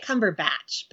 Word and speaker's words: Cumberbatch: Am I saying that right? Is there a right way to say his name? Or I think it's I say it Cumberbatch. – Cumberbatch.